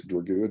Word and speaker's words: Dragoo: I had double the participants, and it's just exploded over the past decade uh, into DART, Dragoo Adventure Rider Training, and what we do now.